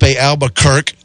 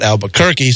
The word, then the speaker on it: Albuquerque: So anyway, the other car, the other Cadillac, the Mustang team, with Joel Barbosa and Felipe Albuquerque, not Albuquerque, so now I can pronounce that whole team correctly, finished 11th.